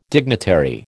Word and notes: dignitary: American English

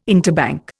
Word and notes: interbank: Indian

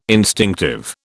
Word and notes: instinctive: British English

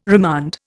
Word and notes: remand: Indian

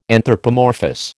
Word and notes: anthropomorphous: American English

